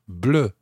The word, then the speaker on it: bleue: B-L-E-U. So, un livre bleu, a blue book, B-L-E-U, une voiture bleue. Exactly the same pronunciation, but it's got an extra E on the end, B-L-E-U-E.